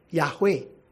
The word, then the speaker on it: Yahweh: Two-syllable is pronounced, therefore, as Yahweh. Or three-syllable becomes Jehovah.